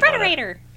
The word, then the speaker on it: Frederator: So I didn't Frederator.